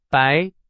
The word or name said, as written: 白